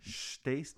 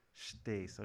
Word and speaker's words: Stace: Now that's Stace, not Stace. – Yeah. Okay. Rhymes with ace. – Stace, okay.